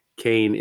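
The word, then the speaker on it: Kean: If you look at this on paper and you're not familiar with either of the D3 teams, you'd think it was Keene versus Dean. They're both spelled the same way, but Kane is how the North New Jersey school is pronounced.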